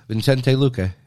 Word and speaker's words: Vicente Luque: Vicente Luque.